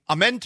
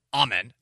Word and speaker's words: Amen: Amen Thompson, and apparently the pronunciation is Amen, not Amen. Which is interesting because I was at a church that was pronouncing amen, amen, which I'm like, shut up, heathens, you're wrong.